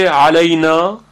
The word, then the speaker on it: علینا: در کلمه علینا حرف عین از میان حلق وسط حلق تلفظ میشه علینا، یک اندک فشاری در وسط حلق وقتی ما ایجاد بکنیم عین به راحتی تلفظ میشه علینا.